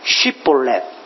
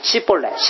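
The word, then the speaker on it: 쉬볼렛 (שיבולת): 그래서 이 에브라임 사람들은 쉽이라는 발음을 잘 못해가지고 씨볼레 씨볼레 심지어는 이것을 쌍시옷으로 발음해가지고 좀 욕같이 들리지만 씨볼레 씨볼레 이렇게 발음하는 거예요.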